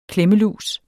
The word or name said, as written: klemmelus